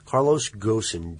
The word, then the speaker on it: Carlos Ghosn: Carlos Ghosn, G H O S N, uh, the Nissan Motor Chief Executive.